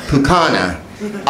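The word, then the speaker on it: pūkana: I did the, is it Puka or Puka? How do you say it? – Pukana. – Pukana.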